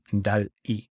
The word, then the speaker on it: DALL-E: Y sobre contar historias, pues también de OpenAI sale un segundo motor de inteligencia artificial basado en la imagen que se llama DALL-E, de Dalí, como el pintor, pero se escribe DALL-E.